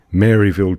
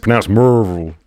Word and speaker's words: Maryville: You know, I mean, I remember I was playing in, like, at the Kaufman camp in Maryville, Tennessee, pronounced Merville.